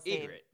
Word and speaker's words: Ygritte: And then what's the other girl's Ygritte. – Name? Ygritte.